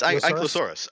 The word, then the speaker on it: Ankylosaurus: Uh, it's Ankylosaurus.